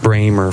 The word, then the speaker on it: Brahma: The Brahma, pronounced Bramer for as long as I remember, stands smoky-hided and a head above the rest.